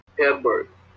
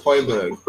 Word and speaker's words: Højbjerg: And he mistakenly hit Højbjerg. – Yeah. – I hope I said that right. – No, you didn't. It's Højbjerg. It's Højbjerg.